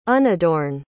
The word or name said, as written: unadorn